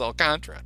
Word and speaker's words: Alcantara: and people got really mad at Len, saying you're pronouncing it wrong, and he was basically like, well, you know who I asked? I asked him. And he said it's Alcantara, so I'm going to call him Alcantara.